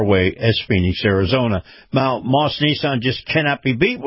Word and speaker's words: Maus: And by the way, this portion of the program brought to you by Moss Nissan. Moss is spelled M-A-U-S.